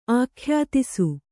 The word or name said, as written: ಆಖ್ಯಾತಿಸು